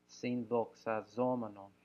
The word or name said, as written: sinthoxazomenon